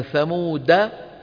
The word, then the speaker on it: ثمود: ألا إن ثمود كفروا ربهم، وإذا وقف عليها ألا إن ثمود بدال ساكنة، والألف تكتب ولا تلفظ على رواية مين؟ حفص. وضح؟ علامتها كما ترون السكون المدور فوق الألف، نجد السكون المدور، يعني هذا الحرف لا يلفظ لا وصلا ولا وقفا.